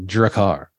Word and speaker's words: Drakkar: Rob says as kids we were on that Drakkar. – Yeah. – You got to say it like that. Drakkar.